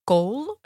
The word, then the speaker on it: kohl: K-O-H-L. – K-O-H-L. – Kool. niet gewoon K-O-O-L? – Nee, maar dat zou, dat zou kool.